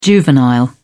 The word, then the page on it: juvenile: British English